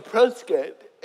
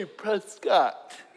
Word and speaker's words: Prescott: 2 weeks ago, I was in Prescott, and I was reminded nicely, you don't say Prescott. It's Prescott, like biscuit. I'm like, well, that's not the way it's spelled, but okay.